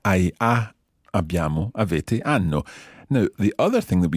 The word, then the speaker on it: hanno: O, ai, a, abbiamo, avete, hanno. – Okay, very good. Just one thing. In Italian, we don't pronounce H. – Of course. – It's mute. So instead of hanno, it would be hanno.